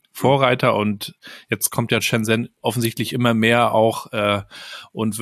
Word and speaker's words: Shenzhen: ein Buch gelesen über Shenzhen, wenn ich es richtig ausspreche. Wie spricht man es richtig aus? – Shenzhen, ja. – Shenzhen, ja.